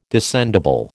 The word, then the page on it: descendible: American English